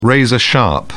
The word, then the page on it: razor-sharp: British English